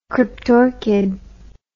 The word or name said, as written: cryptorchid